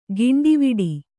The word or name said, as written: ಕೊಂಡವಾಡೆ